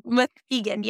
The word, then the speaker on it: Muskegon: Is that how you say it? Muskegon? – Muskegon, yes.